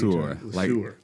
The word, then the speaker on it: LeSueur: LeSueur. – We've debated over this. – LeSueur. Like the peas, you know.